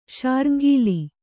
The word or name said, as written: ಶಾರ್ಙ್ಗೀಲಿ